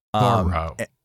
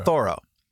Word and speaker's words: Thoreau: Thoreau. – Okay. Thoreau. – Like the comic book character Thor with an O? – Oh, a bit.